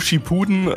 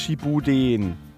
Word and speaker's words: Shippuden: Also ich, ich finde, äh, äh, was ich so mitbekommen habe, ist äh, Naruto, äh, Naruto Shippuden ähm, ist äh, ja dann doch äh, besonders zum Ende hin recht düster geworden. – Ähm, warte mal kurz, du hast es falsch ausgesprochen. Es heißt Shippuden.